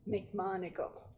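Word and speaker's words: McMonagle: McMonigle. – Spell that for me. – M-C, capital M-O-N-A-G-L-E.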